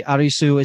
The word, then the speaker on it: Arisu: So obviously, Arisu is Alice, and for those listening that aren't really familiar with the Japanese culture, it's a very it's a very known thing that L's are difficult to pronounce, and they're usually substituted with like an R.